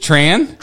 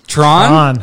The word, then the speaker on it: Thran: I'm going to say Thran, but is it Tran? – Tron. – Tron? – Tron. – Tron. – So like the movie. – We had Austin on here last. I couldn't pronounce his last name correctly. – Yeah, that's okay. – Jared calls you Tran. I say Thran because it's T-H-R-A-N. – Yep. – It's Tron. Tron.